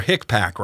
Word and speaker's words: HICPAC: Now, you, you have some big problems with the Healthcare Infection Control Advisory Committee, or HICPAC, right? Is that how you say it? – Yeah, that's that's how you say it.